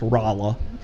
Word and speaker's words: Rolla: It's actually pronounced Rolla.